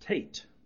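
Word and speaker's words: Teth: And this is the part that begins with the ninth letter of the Hebrew alphabet, and it's pronounced Tet, although spelled T-A-T-E-T-H.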